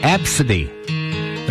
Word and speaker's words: ABCDE: The name ABCDE, which is pronounced Absidy.